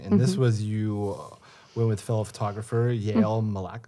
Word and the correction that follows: Malka: How do you say it? Yael Malka. – Malka.